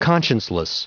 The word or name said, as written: conscienceless